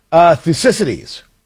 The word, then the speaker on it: Thucydides: I pronounce it Thucydides, and you may too.